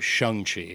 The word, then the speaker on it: Shang-Chi: Which also figured out that's the correct pronunciation. Is Shang-Chi, oh, not Shang-Chi.